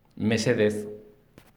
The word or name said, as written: mesedez